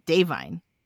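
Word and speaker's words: Da'Vine: It's not Divine Joy Randolph. It's Davine. Like that. We've been pronouncing it incorrectly. – Oh, okay. It's not like divine, like the word divine. It's So Davine. Get on that.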